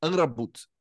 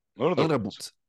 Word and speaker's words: Amrabat: A pronúncia mais próxima ali do, do que eles falam, e eu também tô, consigo falar o certinho, é algo assim: sufian Amrabat. Amrabat.